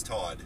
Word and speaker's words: Todd: But the key reason there is that, my name is Todd, obviously.